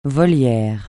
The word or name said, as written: volière